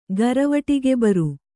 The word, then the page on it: ಗರವಟಿಗೆಬರು: garavaṭigebaru